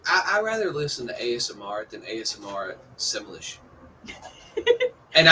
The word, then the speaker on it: ASMR: Now I reckon it wouldn't take long for them to do an Asmir, if that's how you pronounce it. I'll call it Asmir. – It's ASMR. It's just the letters. It's ASMR.